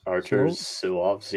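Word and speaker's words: Arturs Silovs: But, I mean, how do you say his name? Silvas? – Arthur Silvas, yeah.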